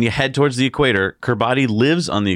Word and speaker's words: Kiribati: Kiribati. No, it's pronounced Kiribati. – What, that's fun. The T is pronounced with an S? – I don't know why.